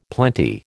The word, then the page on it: plenty: American English